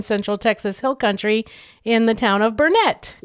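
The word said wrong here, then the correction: Burnet: Well, actually, it's Burnett, darn it. Oh. If you want to say it right. – We mispronounce every Texas name. We still can't get New Braunfels right. – Yeah, it took me a long time. You know, there's a saying, it's Burnett, darn it, learn it.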